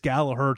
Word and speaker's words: Gallagher: And I had someone over in Ireland say, yeah, the G is silent. So it is Gallagher taking on Chinzo Machida.